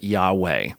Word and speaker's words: Yahweh: This is what's called the Tetragrammaton, these four Hebrew letters in the Old Testament, and we put some vowels with those Hebrew characters, those Hebrew consonants, and we pronounce it as best we know. We pronounce it Yahweh.